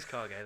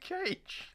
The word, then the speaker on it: Kage: You actually pronounce it Kage. – It is Kage. – It's I've always called it Barney and Cage. – It's Kage. It's the fucking Japanese word for shadow, you fucking mongoloid.